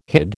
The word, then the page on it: hid: American English